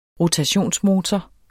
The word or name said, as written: rotationsmotor